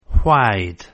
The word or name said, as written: why'd